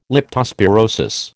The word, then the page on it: leptospirosis: American English